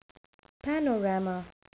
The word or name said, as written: panorama